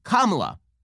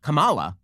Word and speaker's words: Kamala: Because it's pronounced Kamala, not Kamala.